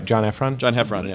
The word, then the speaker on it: John Heffron: I'm saying that right. John Heffron.